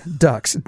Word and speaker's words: Duhks: It's spelled the, traditional spelling, Ducks, D-U-H-K-S.